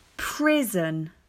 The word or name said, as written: Prison